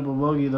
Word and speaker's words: Bulgogi: Bulgogi. – I've been burping up bulgogi the whole time. You don't smell that. – He still can't say it right.